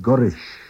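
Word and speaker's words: goll rish: Cré, gulrish, tan emshir. Cré, gulrish, tan emshir. What's the weather like? Gulrish, which is always pronounced gurish in normal speech, means going to.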